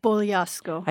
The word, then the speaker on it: Bogliasco: Did I say that right? – Bogliasco. – I didn't say that right, but I'm leaving that in.